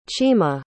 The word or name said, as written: chima